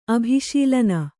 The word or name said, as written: ಅಭಿಶೀಲನ